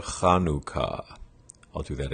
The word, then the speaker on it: Chanukah: And the ch sound at the beginning of Chanukah is the same ch sound as you have at the end of Johann Sebastian Bach.